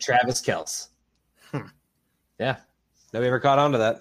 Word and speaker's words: Kelce: Yeah, no, it's Kels.